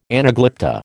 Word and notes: anaglypta: American English

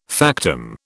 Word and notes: factum: British English

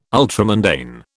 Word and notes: ultramundane: British English

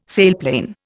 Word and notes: sailplane: Indian

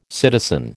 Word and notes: citizen: American English

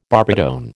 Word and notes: barbitone: American English

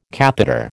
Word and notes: catheter: American English

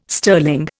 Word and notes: sterling: Indian

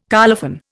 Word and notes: colophon: Indian

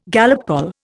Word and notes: Gallup poll: Indian

